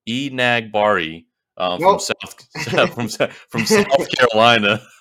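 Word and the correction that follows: Enagbare: So it's Enagbre. – See, I got it wrong.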